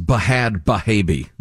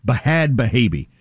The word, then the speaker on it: Bhad Bhabie: I believe it's pronounced Bahad Bahabi. – B-H-A-D-B-H-A-B-I-E. Bahad Bahabi.